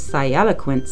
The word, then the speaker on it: sialoquent: It is spelled S-I-A-L-O-Q-U-E-N-T. It is pronounced sialoquent.